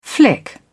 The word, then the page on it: flick: British English